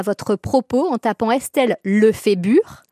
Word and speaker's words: Lefébure: Non, Lefébure. Je suis très fière de mon nom de famille en plus. On ne va pas l'écorcher. – Alors, Estelle Lefébure, votre livre s'appelle « Welcome, accueillir le temps qui passe ».